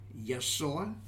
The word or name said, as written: Yasoa